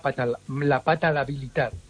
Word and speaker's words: palatabilidad: palatabilidad. No lo he dicho bien. – Palatabilidad. – Eso. – Sí, de palatal. Eso.